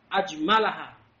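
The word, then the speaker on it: Ajmalaha (أجملها): Keimanan allati ajmaluha ahwa. Ini bukan isim tafdil. ini fi'il, bukan ajmaluha. Gimana bacanya? Ajmalaha.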